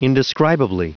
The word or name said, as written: indescribably